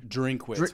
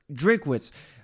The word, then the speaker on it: Drinkwitz: Drinkwitz. I've been correct. Yeah, I've been corrected a couple times now. I better get his name right since he started trolling my boys here in Knoxville. – Drinkwitz.